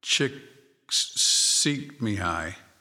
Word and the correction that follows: Csikszentmihalyi: Let's try that again. Mihai Csikszentmihalyi. It's a Czech name and hard to pronounce, but the book is called Flow.